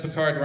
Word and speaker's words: Picard: I mean, you have to point out that Picard rhymes with Descartes, so...